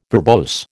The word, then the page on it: verbose: American English